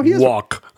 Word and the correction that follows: Wouk: no, it, it is pronounced woke.